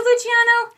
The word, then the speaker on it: Luciano: Is it Luciano? I thought it was, but like C-I is H, and so there's no other I after that. But I was like...